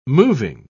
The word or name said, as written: moving